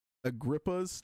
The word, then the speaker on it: Agrippa: but it's a Cornelius Agrippa's charm. I think that's how you say it, is Agrippa.